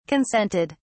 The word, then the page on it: consented: British English